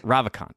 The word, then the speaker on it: Ravikant: Ravikant. Okay, great. And then I was just going to introduce. G-S-E-N,